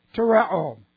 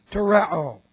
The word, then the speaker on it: tereo: Okay, we have the aorist active indicative of tereo. And boy, this is where we start some fun. Tereo. T-E-R-E, long O. Tereo.